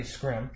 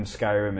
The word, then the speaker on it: Skyrim: Scrim.